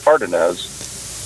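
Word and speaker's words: Cárdenas: I was listening in on the hearing, and that came up by two Democrats in California, Tony Cardenas. I've also heard it pronounced Cardenas. But I think it's Tony and yes.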